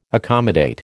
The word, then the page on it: accommodate: American English